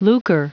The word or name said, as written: lucre